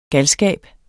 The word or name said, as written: galskab